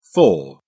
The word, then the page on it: fore: British English